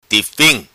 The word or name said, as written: Tifim